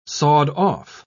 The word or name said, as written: sawed-off